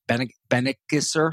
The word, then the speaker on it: Benckiser: acquired by Reckitt Benckiser. Hopefully I said that right. Benckiser? – Benckiser. Close enough.